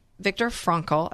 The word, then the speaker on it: Viktor Frankl: But I have a big question that's been circling in this noggin of mine, and it is about Viktor Frankl. I said it right this time, right? Yes. – Very good. Gold star.